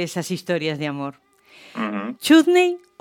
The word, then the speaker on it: chutney: Entonces me gusta pronunciarla de manera británica, chutney.